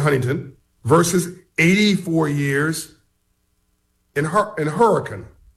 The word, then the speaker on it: Hurricane: And I don't think it's pronounced Hurricane. That's an interesting name. – Hurricane.